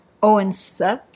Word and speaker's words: Odense: He came from Copenhagen, and my mother and father got married in Copenhagen, and then he got a job in Odense. – Odense? – Yes. – Am I pronouncing that correctly? – Yeah, you're doing very Odense.